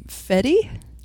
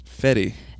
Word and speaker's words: Fede: Directed by Fede Alvarez. I'm assuming that's how he pronounces it. – I watched a tiny little bit of some sizzle stuff and I saw Sam Raimi call him Fetty. – Fetty.